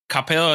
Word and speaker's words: Capella: Hey, you went to Capella, is that how I'm pronouncing it right? – Yep. Okay. That's right.